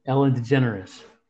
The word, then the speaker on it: Ellen DeGeneres: Ellen DeGeneres.